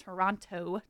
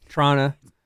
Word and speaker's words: Toronto: I pronounce the second T so much so. – Yeah, it doesn't get pronounced. Toronto.